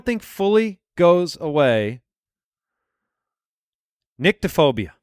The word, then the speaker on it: nyctophobia: It's N-Y-C-T-O. Nyctophobia. That's how you pronounce it.